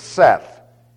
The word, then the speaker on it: saith: Now you notice I pronounced it Seth. And the reason why I pronounced it Seth is because it is equivalent to an English word that we now use where we've dropped the TH, S-A-I-D. And we pronounce this said.